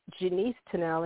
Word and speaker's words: Janice Tunnell: And Denise is Denise at Denise Tunnell, and Tunnell is T-U-N-N-E-L-L. And I am Janice Tunnell, and Janice is spelled J-A-N-I-C-E-T-U-N-N-E-L-L.